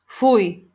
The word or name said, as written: fui